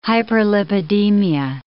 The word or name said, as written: hyperlipidemia